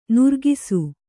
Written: ನೊರಕಿಸು

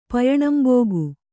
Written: ಪಯಣಂಬೋಗು

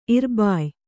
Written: ಇರ್ಬಾಯ

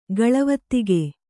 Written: ಗಳವೊತ್ತಿಗೆ

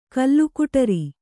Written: ಕಲ್ಲುಕುಟರಿ

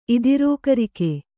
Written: ಇದಿರೋಕರಿಕೆ

